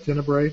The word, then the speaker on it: Tenebrae: Is that Tenebrae? Is that how we pronounce that? – Yeah.